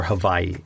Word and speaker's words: Hawaii: So mm I flew to the Big Island or the island of Hawaii or Hawaii as it's actually properly pronounced.